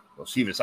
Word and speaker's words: Iosivas: Iosivas.